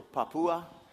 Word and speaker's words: Papua: So you say the three syllables. You say the three syllables. All right. So Papua, New Guinea.